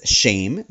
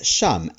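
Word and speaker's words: shame (שם): That's the reason for why the Hebrew word for name is made up of the letters Shin, Mem, which can be pronounced as shame, meaning name, or as shum, as in there you are.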